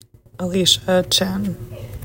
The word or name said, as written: Alysha Chin